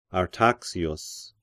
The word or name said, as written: Artaxius